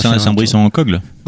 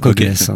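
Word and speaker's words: Saint-Brice-en-Coglès: Alors on prononce Saint-Brice-en-Cogle? Ça se prononce comme ça? – Pas en Coguès.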